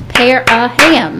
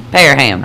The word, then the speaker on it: Parham: But you got to clap it out like you do in kindergarten. You see the syllables. It's Parham. – Yes. Parham.